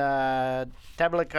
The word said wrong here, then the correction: Tabacalera: I'm sure I'm saying that wrong. As with the Tabacalera? – Hey, Tabacalera. There you go.